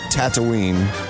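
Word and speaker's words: Tataouine: And it's actually called Tatooine, although it's spelled differently.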